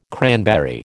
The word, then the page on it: cranberry: American English